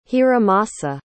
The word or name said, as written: hiromasa